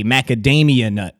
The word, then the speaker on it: macadamia: Macadamian, and it's macadamia. M-A-C-A-D-A-M-I-A, macadamia nut. – There's no N or M at that.